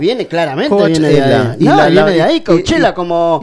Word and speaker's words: Coachella: Coachella. – ¿Así también? – Coachella.